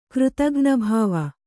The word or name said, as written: ಕೃತಘ್ನಭಾವ